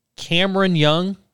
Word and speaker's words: Cameron Young: 9,100, Cameron Young.